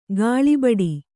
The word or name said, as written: ಗಾಳಿಬಡಿ